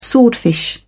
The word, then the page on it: swordfish: British English